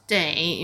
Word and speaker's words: 得: when this character is pronounced day, it means it needs, it takes.